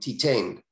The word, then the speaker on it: Titane: And that, as far as a new movie, I would say Titane. It's a French movie. It's spelled like Titan, but with an E at the end, Titane.